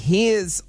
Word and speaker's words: heirs: Not the heirs. – The heirs. – The heirs. – Silent H. – The silent H.